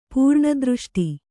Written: ಪೂರ್ಣದೃಷ್ಟಿ

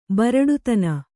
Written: ಬರಡುತನ

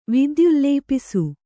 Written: ವಿದ್ಯುಲ್ಲೇಪಿಸು

